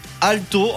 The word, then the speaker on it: Aalto: Et oui, bonjour, c'est l'heure de l'histoire incroyable et direction ce matin l'université Aalto en Finlande, j'espère que je prononce bien.